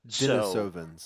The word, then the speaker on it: Denisovans: and Denisovians. I'm probably not saying that right. Denisovians in Asia. – Denisovans. – So, Denisovans. Yeah.